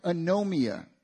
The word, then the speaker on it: anomia: The word lawlessness in the Greek is the word anomia. It's spelled A-N-O-M-I-A, anomia.